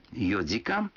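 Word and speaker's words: йодзикан (よじかん): Но будьте внимательны, некоторые цифры в этих случаях произносятся не так, как обычно. 4 часа – это йодзикан, 7 часов – ситидзикан, а 9 часов – кудзикан.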